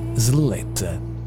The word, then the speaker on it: Sletta: Si chiama Sletta, si pronuncia così, si scrive perlomeno così, S-L-E-T-T-A, Sletta, Good Science.